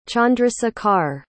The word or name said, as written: chandrashekhar